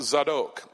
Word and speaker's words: Zadok: I have it on good authority that it is not the Louisiana Central, Louisiana redneck version of Zadok that I had in my mind. There are no long A's in the Hebrew language. It is pronounced Zadok. That is the correct Hebrew pronunciation.